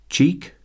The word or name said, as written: cheek